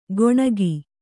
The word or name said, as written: ಗೊಣಗಿ